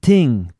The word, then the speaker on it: ting: T-I-N-G, ting. Ting es una palabra patois, que es una abreviación. ¿Cómo se dice? – Abbreviation. – Una abreviación. Bueno, esa sí fue difícil. De thing, de cosa. T-H-I-N-G, thing. Ellos lo dicen ting.